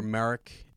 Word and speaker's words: Marek: So Marek. Yeah, like okay. Kind of, I think it's spelled... Starkiller's name is spelled, so you have Galen and then M-A-R-E-K, Marek. And then you've got this guy, Marok, I guess is M-A-R-R-O-K.